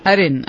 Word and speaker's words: Arin: A-R-I-N. – Arin.